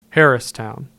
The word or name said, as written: Harristown